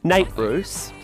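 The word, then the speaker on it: Nate Ruess: Versus Just Give Me a Reason featuring Funs Nat Roos from, I don't know what the album was. – Nate Roos. Please say his name with some respect. – Nate Roos, sorry.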